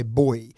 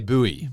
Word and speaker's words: buoy: The first one is the pronunciation of B U O Y. I say boy. – Yeah, but but Americans say buoy.